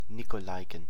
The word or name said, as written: Nikolaiken